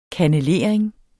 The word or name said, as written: kannelering